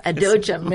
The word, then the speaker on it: Adochim: Adochim, yes.